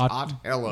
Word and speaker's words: Othello: I can do Otello, but I can't do Richard. – Othello. It's pronounced Othello.